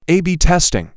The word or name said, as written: a b testing